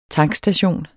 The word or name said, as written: tankstation